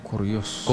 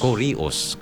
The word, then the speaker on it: Kurios: Itong Lord na ito ay galing naman sa Gregong Kurios. – Kurios. – Kurios, kung at sa pronunciation. Kurios.